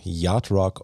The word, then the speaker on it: Yacht Rock: Ich habe natürlich ein Thema mitgebracht und zwar geht es heute um das Thema Yard Geschrieben wird das Yachtrock und äh, diesen Begriff haben vielleicht auch einige Hörerinnen und Hörer schon mal irgendwo gelesen. Die korrekte englische Aussprache ist aber eben Yardrock und als gelernter Englischlehrer möchte ich das natürlich auch gern richtig aussprechen.